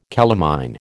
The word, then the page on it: calamine: American English